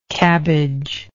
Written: cabbagelike

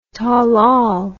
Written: tollol

